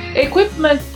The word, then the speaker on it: equipment: O P é mudo, ele praticamente não sai o som. Equipment, ok? Assim como o T final.